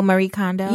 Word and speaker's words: Marie Kondo: You know, I'm kind of on this, you know, Maria, how do you say it? Maria Kondo? – Oh, Marie Kondo.